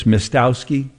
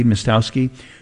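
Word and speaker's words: Miestowski: Mistowski, I think is how you say her name, M-I-E, Mistowski, before shouting, "Do not touch my new driver."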